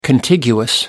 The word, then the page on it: contiguous: American English